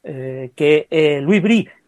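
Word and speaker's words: Louis Brus: Louis Brie se escribe Bruce,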